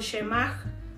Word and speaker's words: Shema: Durante miles de años el pueblo judío, judío perdón, ha orado el Shema o, pronunciado Shemach, una oración diaria de devoción a Dios, y esta es Deuteronomio 6, del 4 al 5.